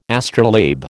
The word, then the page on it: astrolabe: American English